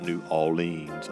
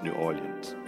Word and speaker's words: New Orleans: It's not New Orleans or New Orleans, it's New Orleans.